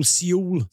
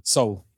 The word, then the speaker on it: Seoul: Is it Seoul? Seoul? – Seoul. – It is Seoul.